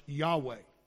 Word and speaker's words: Yahweh: And that word is Yahweh, and it's spelled in the Hebrew YHWH, pronounced Yahweh.